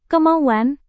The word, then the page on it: kemauan: indonesian